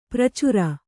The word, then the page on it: ಪ್ರಚರ: pracara